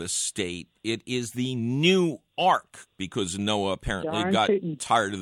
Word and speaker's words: Newark: Much closer, really, to Newark, Delaware, where you must put that accent on the second syllable.